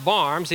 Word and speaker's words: Worms: It's pronounced Worms, even though it looks like worms.